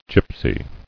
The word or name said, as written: Gipsy